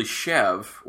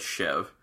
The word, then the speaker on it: Chèvre: Is Chèvre cheese, which actually Chèvre, or it's spelled C-H-E-V-R-E, so people say Chèvre, but it's, I believe it said Chèvre. It's the French word for goat.